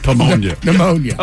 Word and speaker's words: pneumonia: So, Miles, you said it correctly. Pneumonia, not pneumonia. It's like pterodactyl. – Okay. – Correct. So the IA is A. – Right. – It's like a deaf person going to the bathroom. The P is silent.